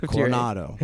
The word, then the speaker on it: Coronado: Did I say that correctly? – Yes, Coronado, Crown City.